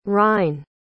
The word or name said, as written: rine